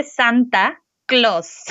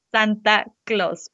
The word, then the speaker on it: Santa Claus: Aquí se dice Santa Claus, Santa Claus, porque lo pronunciamos siempre con una forma un poco tergiversada de lo que es el inglés.